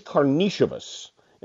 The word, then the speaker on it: Karnišovas: He was always Karnišovas, and I guess as now we know, the more traditional, the more correct Lithuanian pronunciation would be Karnišovas.